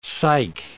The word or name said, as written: sike